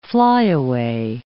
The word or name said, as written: flyaway